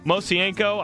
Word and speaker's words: Mosienko: Mosienko, I think that's how you pronounce his name. You got it.